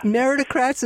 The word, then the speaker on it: Meritocrats: Meritocrats, is that how you do it? – Yeah, yeah, it's like in meritocracy.